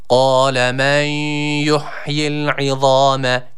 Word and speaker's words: قال: Kale şeklinde sıkça okunuyor. Yanlış bir okuyuştur. Dikkat edelim. Kaf harfi var. – قال. – Kale yanlış olur.